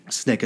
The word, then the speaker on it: SNCC: SNCC is how you pronounce that.